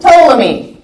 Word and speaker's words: Ptolemy: The two that we care about, Seleucus and Ptolemy. The P is silent.